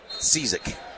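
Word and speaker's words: Książek: I'm going to say that you would pronounce Tiziak? – K-S-I-A-Z-E-K as Cizek. I'm going to say that's Alex Cizek.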